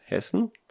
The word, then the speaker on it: Hessen: But anyhow, the state where I live here is Hessen, is the German pronunciation, I guess. English Hess, I'm not sure.